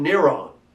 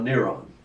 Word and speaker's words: Neron: We know that because of the Greek spelling and pronunciation of Nero, or in Latin, Neron. Not moron, Neron.